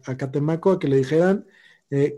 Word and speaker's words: Catemaco: Tiene que ir a Catemaco para que se lo hagan. ¿Qué dijiste? Que tiene que ir a, Catemaco. Lo dije bien la primera vez y trabarme, y gracias a que me cuestionaste, vali verga.